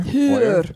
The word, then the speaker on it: Heuer: Heuer. – Heuer.